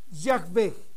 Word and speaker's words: Yahweh: Así es que, si somos honestos, cuando el traductor nos pasa la palabra Jehová, que debemos pronunciar correctamente Jehová, si esto fuera real, observen ustedes, Jehová Yahweh, Jehová Yahweh, nos damos cuenta, queridos hermanos, que es un error de pronunciación.